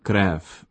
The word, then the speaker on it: krev: No overview of the Czech language comic scene would be complete without a mention of krev, spelt crew in English but pronounced as the Czech word for blood.